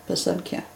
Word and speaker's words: Pesanki: The emphasis is on the ki, Pesanki.